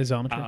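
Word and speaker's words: Xometry: I think it's pronounced Zometry or Zometry. – Okay, Zometry.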